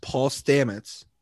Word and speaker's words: Paul Stamets: psilocybin mycologist Paul Stamets.